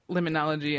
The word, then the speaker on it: limnology: So first, what is limnology? And am I even pronouncing that right? – Yes, it's limnology. – Limnology itself is the study of inland waters, so lakes, streams, and wetlands.